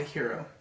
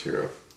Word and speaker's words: gyro: And for fuck's sake, if you haven't watched John Wick and eat a Euro. – It's called a Hero. – That's what I said. – You said Euro. – It's spelled G-Y-R-O. – So eat a Giro. – It's pronounced Hero.